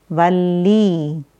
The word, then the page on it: Valli: Sanskrit